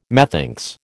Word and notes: methinks: American English

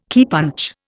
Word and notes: keypunch: Indian

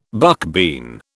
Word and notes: buckbean: British English

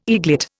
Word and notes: eaglet: Indian

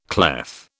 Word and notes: clef: British English

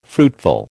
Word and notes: fruitful: American English

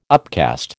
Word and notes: upcast: American English

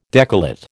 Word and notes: decollate: American English

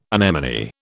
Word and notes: anemone: British English

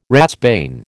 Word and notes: ratsbane: American English

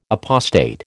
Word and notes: apostate: American English

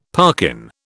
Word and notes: parkin: British English

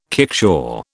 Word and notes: kickshaw: British English

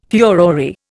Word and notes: furore: Indian

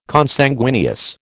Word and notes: Consanguineous: American English

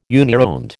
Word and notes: unironed: American English

